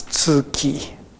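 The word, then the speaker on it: Tsuki (月): It sounds strange, but as a Japanese, we pronounce this as Tsuki, and that means moon.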